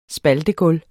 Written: spaltegulv

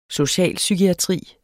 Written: socialpsykiatri

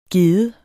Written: gedde

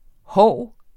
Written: hov

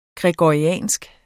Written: gregoriansk

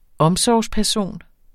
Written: omsorgsperson

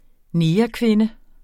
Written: negerkvinde

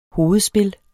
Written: hovedspil

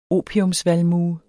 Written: OPIATVALMUE

